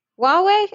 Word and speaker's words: Huawei: Is it Hawaii? – Huawei? – Huawei? – Yeah, it's Huawei.